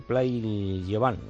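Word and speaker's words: Pla i Llevant: Play Llevan, ¿no? – Efectivamente, tenemos bien. – Muy bien, lo has pronunciado, hombre.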